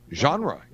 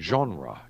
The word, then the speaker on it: genre: It's not genre with the wind. Genre. Yeah, it's a French word. – But it's like a genre. Z. But it says to pronounce it, you say with the Z-H, genre.